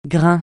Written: grain

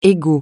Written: ego

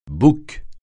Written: bouc

